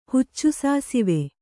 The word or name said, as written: ಹುಚ್ಚುಸಾಸಿವೆ